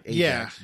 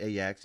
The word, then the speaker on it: Ajax: It's spelled like Ajax, but Ajax, because it's Dutch.